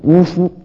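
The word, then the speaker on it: 於戲: 这个和尚说那有什么稀奇啊，你们读孔子儒家的书，大学上明明是于西两个字，你们偏要读成呜呼，你们也呜呼呜呼吗，我就来给你南无南无了。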